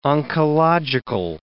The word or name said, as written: oncological